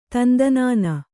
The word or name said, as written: ತಂದನಾನ